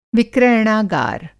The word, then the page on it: विक्रयणागार: Hindi